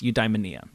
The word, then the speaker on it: eudaimonia: and that word is eudaimonia. – Yeah. – Which I'm not even entirely sure at this point I'm still pronouncing. – No, correctly. That's right.